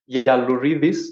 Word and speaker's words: Yiallourides: Yeah, on social media, Miguel the Vegan, but my name is Miguel Yaluridis. That's my Greek surname that most people won't be able to pronounce anyway.